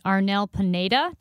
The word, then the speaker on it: Arnel Pineda: What about the fascinating story of the new lead singer, or newish lead singer of Journey, Arnel Pineda? Did I say that right? – Yes, you did.